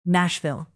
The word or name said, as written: nashville